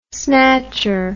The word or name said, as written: snatcher